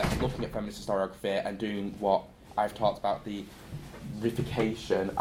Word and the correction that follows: reification: I apologise for the pronunciation, I've only read it. I said reification.